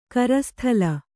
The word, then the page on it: ಕರಸ್ಥಳ: Kannada